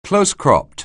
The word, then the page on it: close-cropped: anglais